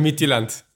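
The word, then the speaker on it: Midtjylland: Yani Liverpool maçı da gene öyle mi olacak dedirtti ama son senin e, ikinci memleketin olan neydi Midland mı nasıl okunuyor? Aynen Midland.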